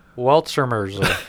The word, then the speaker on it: Weltschmerz: The next band up is called Weltschmerz.